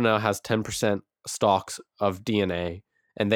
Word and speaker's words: DeNA: They're called, it's spelled D-E-N-A, but it's, so a lot of people have been pronouncing it Dina, but it's actually pronounced DNA.